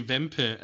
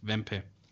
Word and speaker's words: Wempe: No, actually, Wempe is a German brand, German retailer, and that's how you pronounce it, Wempe.